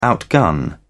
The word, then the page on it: outgun: British English